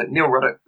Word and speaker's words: Nick Ruddock: And my name is spelt, so obviously N-I-C-K, surname R-U-D-D-O-C-K. So Nick Ruddock.